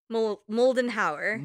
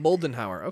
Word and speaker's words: Moldenhauer: Mullenhauer? – Moldenhauer. – Moldenhauer. Okay.